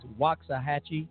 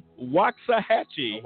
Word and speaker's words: Waxahachie: How do you pronounce that? – I think it's Waxahachie. – Waxahachie.